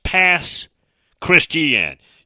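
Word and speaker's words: Pass Christian: Mississippi has many other places, mainly on the coast, that are pronounced, for example, something that looks like Gautier, G-A-U-T-I-E-R, is Gautier. Saucier, S-A-U-C-I-E-R, is Socia. Now, I do know past Christiane. It looks like it's past Christian, but it's pronounced past Christiane.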